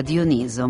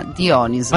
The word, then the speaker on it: Dioniso: Si pronuncia con l'accento sulla penultima sillaba, Dioniso, ma in italiano è considerata corretta anche la pronuncia sulla terzultima, Dioniso.